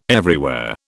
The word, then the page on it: everywhere: British English